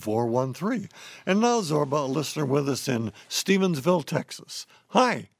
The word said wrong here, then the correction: Stephenville: Hi, there's only one S in Stephensville. – Oh, Stephenville, not Stephensville. Stephenville, I'm sorry.